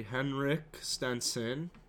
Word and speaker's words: Henrik Stenson: So my picks are going to be Henrik Stenson.